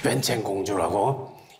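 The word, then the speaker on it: 웬챙공주라고 (文成公主): 16인가 18인가 그 사람을 그 여자를 이제 불러가지고 문성공주라고 그럴 문자 열성자 해가지고 문성공주 중국 발음으로는 웬챙공주라고 이 사람을 이제 보내기로 택했어요.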